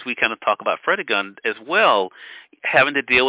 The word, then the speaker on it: Fredegund: And outside of Broomhild, of course, and is the name pronounced Fredegund? – Fredegund.